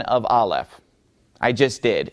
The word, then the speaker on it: Aleph: So Aleph is just a silent letter.